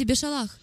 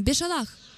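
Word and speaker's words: Бешалах (בשלח): Все скажите Бешалах. Вы произносите как американский еврей? вам надо говорить с ударением на «ах». У румынцев получается превосходно. Давайте еще раз попробуем. Бешалах.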